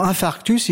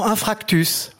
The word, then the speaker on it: infarctus: Hein, pour infarctus, ils diront infractus.